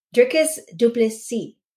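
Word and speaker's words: Dricus du Plessis: How do we say it, properly? – Drikus Duplessis. – Boom.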